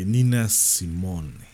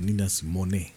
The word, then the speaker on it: Nina Simone: Y hablo de Nina Simone, o Nina Simone, ¿no?, como el quieran pronunciar.